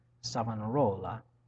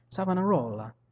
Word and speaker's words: Savonarola: I gotta say his name right. Savonarola. I say it Italian, that works fine. Savonarola. There we go.